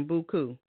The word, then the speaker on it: Buku: Now, real briefly, tell me about your nonprofit organization, Buku. Now, do you say it like that? You say it in the French way?